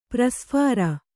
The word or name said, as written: ಪ್ರಸ್ಫಾರ